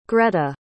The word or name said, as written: greta